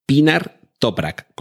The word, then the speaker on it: Pinar Toprak: Se trata de la compositora Pinar Top, mm, a ver que lo pronuncie bien, Pinar Toprak, ¿Cómo suena, como Pinar, donde hay muchos pinos, Pinar Toprak, sin H's ni cosas raras.